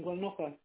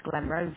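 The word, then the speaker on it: Glenrothes: Rimbleton House in Glenocha.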